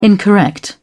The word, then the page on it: incorrect: British English